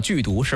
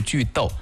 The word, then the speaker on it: 句读: 很多人说太简单了，句读是吧？其实不对，应该是句逗，这个逗通逗号的逗，句逗简单来讲就是断句的意思。为什么要说这两个字呢？因为我们推荐的 APP 跟这两个字有关。先来说这两个字是什么意思，句逗，逗是读书的读，句逗只不过在这个地方它的发音是逗。